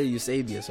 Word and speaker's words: Eusebius: what Eusebius, is that how you say his name, right? – Eusebius. Yeah, Eusebius. Well, Eusebius in Greek.